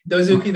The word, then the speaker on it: Dozuki: Oh, or wait, did I say it wrong? I probably said it wrong, didn't I? – Dozuki, that's right. – Okay, yeah. I said it right.